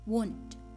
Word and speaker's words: won't: tulisannya itu W O N apostrophe T W O N apostrophe T bacanya won't bukan yang lainnya